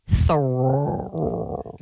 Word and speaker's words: Theroux: Would you like me to say his name correctly for you? – Yes, please. – Louis Theroux.